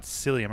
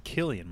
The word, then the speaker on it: Cillian: It's not Cillian Murphy. It's Killian Murphy, I think. – Is it? – I've heard people say that, but I've been saying Cillian Murphy. So I think it's Killian as well.